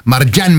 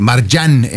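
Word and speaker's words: Marjane: Marjan, me dicen que es como debo pronunciarlo.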